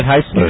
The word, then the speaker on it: Heisler: Am I saying that right? Heisler. – You got it right. – All right. – The two vowels make a long sound.